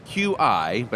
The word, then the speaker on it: Qi: That's QI, but I think it's pronounced Qi. Qi compatible wire.